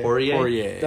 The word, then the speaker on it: Poirier: Poirier.